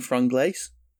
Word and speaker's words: Franglais: Franglais or, yeah, Franglish. Yeah. Like, we say either.